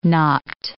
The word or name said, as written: knopped